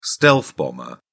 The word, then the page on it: stealth bomber: British English